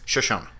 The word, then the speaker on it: Shoshone: I have to Shoshone you the right way to say this. – It's Shoshone.